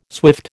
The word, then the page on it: swift: American English